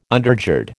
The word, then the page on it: undergird: American English